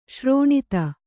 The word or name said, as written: ಸರೋನಿಧಿ